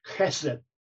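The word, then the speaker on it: Chesed (חסד): It's C-H-E-S-E-D, if you want to try. But you need to have a good guttural sound. Chesed. They say that if the person in front of you doesn't get showered, you're not pronouncing the word chesed properly.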